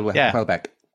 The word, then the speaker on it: Houellebecq: Houellebecq, is it? I've never read Houellebecq. – Him. – Michel Houellebecq.